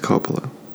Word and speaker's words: Koppula: Patrick Coppola. He's a Coppola. It's spelled K-O-P-P-U-L-A. Government Innovation Consultant. Party preference? None. 14. John Prisner? It's spelled P-R-Y-S-N-E-R.